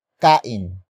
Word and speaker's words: kain: Pagkainan is spelled as P-A-G-K-A-I-N-A-N. It is a command to eat from something. Pagkainan comes from the root word kain.